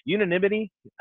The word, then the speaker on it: unanimity: We end up with uh, unit, uh, unit, I can never say that word. Unanimous votes on every unanimity. I think that's the right way to say that. You got it.